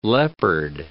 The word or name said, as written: leopard